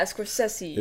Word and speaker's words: Scorsese: Yeah, Scorsese.